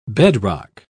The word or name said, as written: bedrock